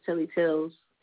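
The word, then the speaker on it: T3lly T3llz: My stage name is Telly Tells. – Telly Tells, okay. – But it's spelled T3LLY, T3LLZ.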